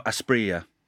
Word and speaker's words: Asprilla: So, Faustino Asprilla.